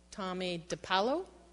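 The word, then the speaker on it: Tomie dePaola: Tommy DiPaolo? Okay. I was right.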